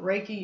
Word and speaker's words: Reiki: So as far as when you're talking about the Reiki, is that how it's pronounced? – Reiki.